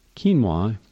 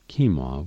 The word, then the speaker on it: quinoa: Quinoa is a wonderful grain. It's a superfood. Why would I recommend that you consume it? Simple. It's a, by the way, it's pronounced keen, K-E-E-N-W-A-W, but it's spelled Q-U-I-N-O-A.